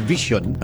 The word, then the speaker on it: VXON: Sa July 17, ang Vision or VXON, ang pronunciation sila is Vision.